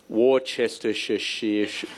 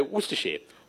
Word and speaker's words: Worcestershire: So normally I'd pronounce it the correct way, which is Worcestershire, or the incorrect way, Worcestershire.